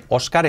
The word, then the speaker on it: Oscar: Oscar, fíjate cómo pronunciamos esta este nombre, Oscar es Oscar. La O casi como una A y la A casi una O porque se la come la R.